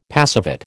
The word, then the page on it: passivate: American English